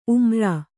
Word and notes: ಉಮ್ರಾ: ummra

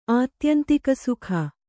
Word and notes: ಆತ್ಯಂತಿಕಸುಖ: Kannada

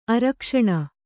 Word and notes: ಅರಕ್ಷಣ: Kannada

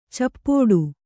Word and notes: ಚಪ್ಪೋಡು: cappōḍu

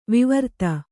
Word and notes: ವೈವರ್ತ: Kannada

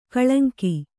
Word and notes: ಕಳಂಕ: kaḷaŋka

